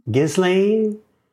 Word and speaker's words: Ghislaine: Am I pronouncing that correctly? – Ghislaine.